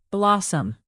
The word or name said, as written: blossum